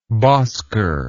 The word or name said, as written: bosker